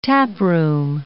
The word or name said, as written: taproom